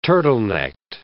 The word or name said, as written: turtlenecked